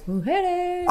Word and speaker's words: Mujeres: I've always called it Isla Mujeres or something. – Mujeres? – Because soft J. – It's a soft J. – Haven't you listened to a Pitbull song ever? He says Mujeres.